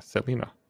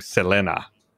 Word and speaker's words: Selena: Someone else in chat, Jasper127, asks, why are they pronouncing Selena like that? Because that's how you pronounce it. Yeah, Selena. I remember when she died, a lot of the Spanish language news channels. She was really only, I guess, mainly popular on the border and like in northern Mexico. And a lot of the Spanish language channels, when they were reporting on her death, pronounced her name Selena.